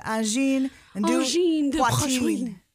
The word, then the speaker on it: Angine de Poitrine: Yes. Say it correctly. – Angine de Poitrine. – There we go. – I don't know if I said it correctly, but we tried. – That's really close.